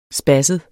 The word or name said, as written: spasset